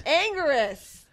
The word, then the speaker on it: Anguirus: Anguirus.